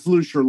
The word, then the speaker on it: Lusher: Last name's Lusher, by the way. I know it's a it's often mispronounced, so it's Lusher, like Lucifer, but no F.